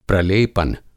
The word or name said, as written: प्रलेपन